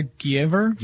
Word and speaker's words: Giaever: Also, the second of the scientists at the GE laboratories, Dr. How would you pronounce his name? Ivari Glaver? – No, Ivar Giever? – Giever? – I have no idea. – He got the Nobel Prize in physics. His last name is spelled G-I-A-E-V-E-R.